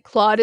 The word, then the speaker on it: Claude: Like Claude is how it's pronounced.